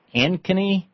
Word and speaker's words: Ankeny: David in Ankeny, Iowa? Am I saying that right, David? What's up? – That's correct.